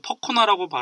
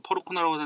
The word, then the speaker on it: Percona: 그 다음에 또 하나 퍼르코나 뭐 퍼코나라고 발음하기도 퍼르코나라고 하는데 그게 있고요.